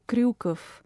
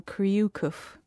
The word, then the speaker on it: Kryukov: I think probably one that's deceptively simple-looking when you see it written down, but it's Nikita Kryukov, because you don't get that ryu sound in English, obviously, because we don't roll the R, so it ends up having to be an extra syllable, Kryukov.